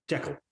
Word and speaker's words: Jekyll: Jekyll. – The correct pronunciation apparently is Jekyll, and they say according to film.